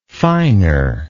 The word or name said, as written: finer